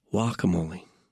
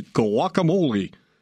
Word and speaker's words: guacamole: Plus, I know how to pronounce it correctly, too. Guacamole. I hate Mexican food restaurant ads where the ad agency gets some guy with, you know, great voice, but he says, guacamole. Ugh. Sends chills down my spine. Your Mexican food restaurant, you're not supposed to pronounce it that way.